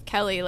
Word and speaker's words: Kelly: Well, everything rhymes with Kelly, like smelly and jelly and belly and...